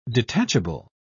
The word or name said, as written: detachable